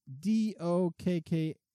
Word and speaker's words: Dokkaebier: I feel like you're not pronouncing that right. – I probably am not. But as an Oakland brewery, but it looks like they're a Japanese brewery, but they're Oakland. And it's Dokkaebier. And they're a brewery out of Oakland.